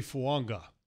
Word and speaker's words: Fuanga: Talese Fuanga, Oregon State. – It's actually Fuanga. – Yes. – There's an N in there that's in the pronunciation that's not in there. – Thank you. – In the spelling.